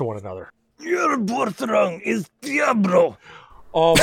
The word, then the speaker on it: Diablo: It's it's it's pronounced Diablo. – Oh, I'm sorry. It's Diablo.